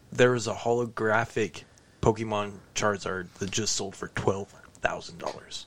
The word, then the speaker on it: Charizard: Charizard? – Yeah, Charizard.